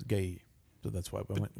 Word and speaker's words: Galil: By the way, it's spelled G-A-L-I-L. I think it's Gailil. – It is Gailil. – Gailil? – Yeah. Well, in France. In American, it would be Galil.